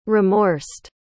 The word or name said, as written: remorsed